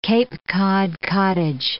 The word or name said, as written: Cape Cod cottage